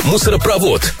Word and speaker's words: мусоропровод: Как произнести правильно? Мусоропровод или мусоропровод? Поразмышляйте несколько секунд. Хоть в большинстве новостроек про них, к счастью, забыли, правильно говорить «мусоропровод». Как запомнить? А просто представьте, что все, что вы туда бросаете, падает в самый низ, то есть в конец слова, в том числе и ударение. Главное, чтобы мусоропровод не забили всякой ерундой, тогда будут проблемы, но уже не с ударением.